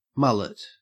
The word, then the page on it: mullet: Australian English